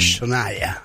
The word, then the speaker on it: Shania: El nombre es Shania. – Shania. – Pues se escribe Gina, ¿no? – Porque... Sí, se escribe Shania.